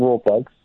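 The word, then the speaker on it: Rawlplug: The L, I'm pretty sure the L is silent. I'm pretty sure it's just rule plugs. – It's not. It's rule plugs. – But I'm pretty sure that L is silent. It's just raw. If I go into a shop and say I need some raw plugs.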